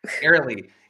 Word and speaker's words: Arely: We have her manager, Ariel Bratton. I'm hoping I'm pronouncing that correct. – Airlie Bratton. – Airlie. I'm messing up locations. I'm messing up names today.